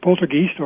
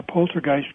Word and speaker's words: poltergeist: 1980, I came across a 10-year anniversary story of a as you pronounce it, poltergeist, or as I pronounce it over here in Canada, poltergeist case, which happened in St. Catharines, Ontario, Canada, in 1970.